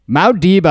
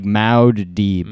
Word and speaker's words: Muad'Dib: Muad'Dib, I actually got uh, pretty close. – Oh, uh, that's what it is. – Muad'Dib. It's like Maud. Yeah, like Maud Deeb.